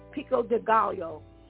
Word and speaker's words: pico de gallo: So, come on, get that pico de gallo.